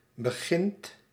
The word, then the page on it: begint: /bə.ˈɣɪnt/